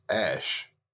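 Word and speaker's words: Æ: He said the AE in the middle is pronounced Ash, and then Joe Rogan's like, "How the fuck do you see Ash?"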